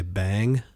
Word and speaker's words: Beyng: And just a reminder that when we say bang, we're pronouncing the word B-E-Y-N-G, which is an alternative spelling of B-E-I-N-G.